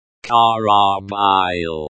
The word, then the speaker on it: корабль: The word karabl, pronounced karabil, means ship in Russian.